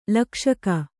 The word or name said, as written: ಲಕ್ಷಕ